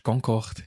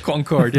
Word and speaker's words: Concorde: Aber noch schnell, ich finde es lustig, dass du das Französisch aussprichst, Concorde. – Ah, habe ich Concorde? Ja, weil ich gerade vorher von der Flug geredet habe. Concorde, ja genau.